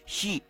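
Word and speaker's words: shi (四): En la cultura japonesa la pronunciación del número 4 se dice shi en japonés. Acá se dice shi, pero a la hamburguesa. Suena muy parecido a la palabra muerte para los japoneses, el shi.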